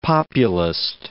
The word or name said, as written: Populist